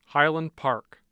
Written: Highland Park

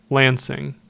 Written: Lansing

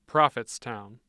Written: Prophetstown

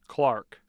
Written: clark

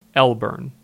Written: Elburn

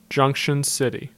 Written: Junction City

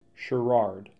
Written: Sherrard

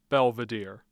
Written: belvidere

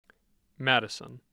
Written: Madison

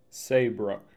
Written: Saybrook

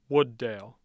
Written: Wood Dale

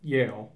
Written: Yale

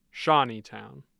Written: Shawneetown

